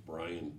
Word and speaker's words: Brien: I knew that my great-grandfather was Patrick O'Brien, so I was named Brian Patrick with that spelling.